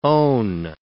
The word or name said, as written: own